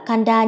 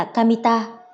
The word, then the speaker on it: Kamita: nhưng nó không đọc là Kanda như anh tưởng, nó được phát âm là Kamita.